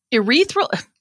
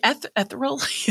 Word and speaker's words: ethereal: So not urethra. Urethra. – Not your urethra.